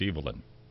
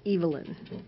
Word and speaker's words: Evelyn: Now, Dean Taylor would tell you that it's Evelyn. – Oh, he'd be absolutely correct. So that's the English pronunciation.